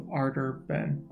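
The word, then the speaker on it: Ardor Benn: It's called The Thousand Deaths of Ardor Ben. Did I pronounce it correctly? – Yeah.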